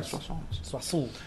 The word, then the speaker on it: Soissons: Supongo que se pronuncia Soissons. – Sí.